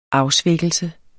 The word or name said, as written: afsvækkelse